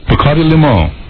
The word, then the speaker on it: Bacardi Limon: Bacardi Limon.